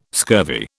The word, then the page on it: scurvy: British English